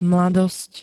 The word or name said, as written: mladosť